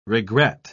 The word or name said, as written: regret